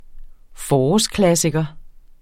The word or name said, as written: forårsklassiker